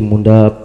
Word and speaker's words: Munda: Mr. Speaker, my name is Busti Munda, page 2, number 53. Munda is spelled M-U-N-D-A.